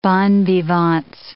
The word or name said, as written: bon vivants